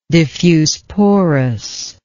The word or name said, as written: diffuse porous